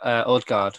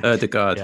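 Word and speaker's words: Ødegaard: If you look at Odegaard, I said that wrong so badly, didn't I? – Odegaard, yeah.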